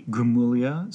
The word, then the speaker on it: Gramuglia: You can find me on Twitter at A Gramulia. So that's A-G-R-A-M-U-G-L-I-A at Twitter. – It sounds like Gramuglia, but the G is silent.